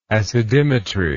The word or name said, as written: acidimetrically